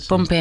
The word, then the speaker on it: Pompe: Yo tengo por aquí otro apoyo, investigación genética en torno a la enfermedad de Pompe, no sé si lo he dicho bien. – Sí, Pompe.